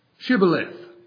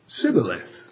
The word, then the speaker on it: Shibboleth: If he said, Nay, then said they unto him, Say now, Shibboleth. And he said, Sibboleth, for he could not frame to pronounce it right.